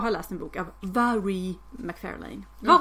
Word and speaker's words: Mhairi: Och att hennes namn is pronounced Vary. – Vary? – Inte Mary alltså, som jag trodde.